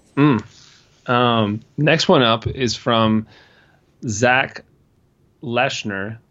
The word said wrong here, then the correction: Leichner: I think I said that wrong. I know how to pronounce his name. – I still Leish- messed up. I think I thought it was Leichner. – Leichner. It's That's how it is.